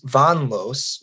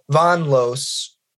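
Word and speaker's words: Von Loos: And so that kind of like, that, I don't know, it helps me, I think it helps a lot of people understand why it's pronounced like that, because it doesn't look like that. But Von Los, it was one word, you know, that it's like a German name.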